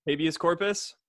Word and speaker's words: habeas corpus: Hibiscus corpus. Do you, is that how you say it? Habeas corpus. Habeas corpus.